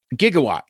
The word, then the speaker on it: Gigawatt: and that is Gigawatt or Gigawatt, depending upon what your preferred pronunciation is. I say Gigawatt because that's how Doc Brown says it in the movie in Back to the Future. Jeremy from Transmissions says Gigawatt because I believe that is actually the proper pronunciation. Technically, Christopher Lloyd is wrong, but for me, it's a quote from the movie. So for me, he's Gigawatt.